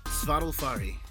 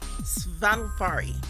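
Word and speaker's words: Svaðilfari: Svadilfari. – Svadilfari.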